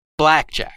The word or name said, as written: blackjack